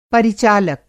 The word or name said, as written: परिचालक